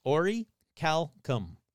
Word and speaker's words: orichalcum: And you're going to say, that's not how it's pronounced. Bullshit. I looked it up like 50 times. It's orichalcum.